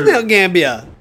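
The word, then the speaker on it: Mount Gambier: It is Mount Gambia.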